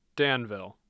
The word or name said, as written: Danville